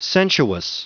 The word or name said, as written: sensuous